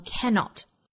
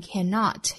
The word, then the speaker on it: cannot: 重读一般都是落在前面这个音节上，读成 cannot, 当然呢它也有读在后面中音的情况啊，读成 cannot 也是有的。